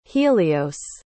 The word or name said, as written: helios